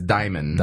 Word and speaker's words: Dimon: Wait, D-I-M-O-N. – D-I-M-O-N. It's Diamond. – Diamond. – You call him DeMond? – Yeah. – Yeah, that makes sense. That's probably how it'd be pronounced in French. – But it's Diamond.